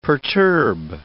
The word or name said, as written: perturb